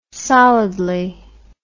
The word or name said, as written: solidly